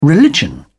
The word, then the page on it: religion: British English